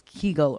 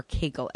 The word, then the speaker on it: Kegel: are called Kegel or Kegel exercises, depending on who you are and where you're from and how you want to say it. But it's spelled K-E-G-E-L.